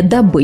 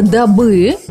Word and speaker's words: дабы: Правильное ударение «дабы». Я пришла к вам, Ольга Васильевна, сегодня «дабы» поговорить с вами о голосе.